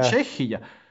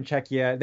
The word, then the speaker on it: Czechia: That's how, by the way, we pronounce it in Latvian, yeah. – Czechia. In American it would be like Czechia.